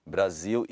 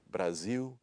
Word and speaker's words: Brasil: And that means that words that are spelled with an L at the end have a W. Even say for me the phrase Brazil and Portugal. – Brasil e Portugal.